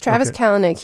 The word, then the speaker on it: Kalanick: Yes, Travis Kalanick.